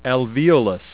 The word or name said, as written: alveolus